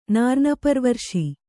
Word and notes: ನಾರ್ನಪರ್ವರ್ಶಿ: kannada

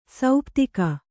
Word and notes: ಸಪ್ತಕ: saptaka

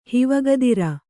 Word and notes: ಹಿವಗದಿರ: kannada

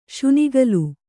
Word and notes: ಶುನಿಗಲು: Kannada

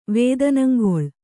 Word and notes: ವೇದನಂಗೊಳ್: Kannada

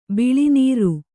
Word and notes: ಬಿಳಿನೀರು: biḷi nīru

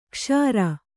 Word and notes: ಕ್ಷಾರ: kṣāra

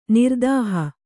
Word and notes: ನಿರ್ದೇಹಿ: kannada

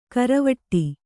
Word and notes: ಕರವಟ್ಟಿ: karavaṭṭi

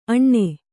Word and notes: ಅಣ್ಣೆ: aṇṇe

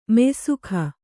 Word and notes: ಮೆಯ್ಸೊಗ: meysoga

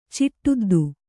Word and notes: ಚಿಟ್ಟುದ್ದು: Kannada

